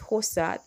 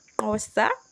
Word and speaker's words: Xhosa: Those are a lot of languages, but I always admire, I don't know, I find it interesting how they pronounce, because we read it as Hosa, that the language that is spelled as X-H-O-S-A. So I'm going to try to pronounce it like they would with the click sound. So I'm pretty sure it would be like Hosa, Hosa.